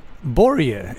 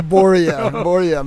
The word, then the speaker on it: Börje: Vi är ju noggranna med uttal, så att Borje, eller hur säger de det? – Ja. Jo, de säger det. Borje. Men jag hade många namn där borta. Det fanns, de försökte säga ö, men de kunde inte säga det.